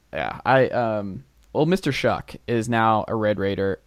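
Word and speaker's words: Shough: It's pronounced Shuck.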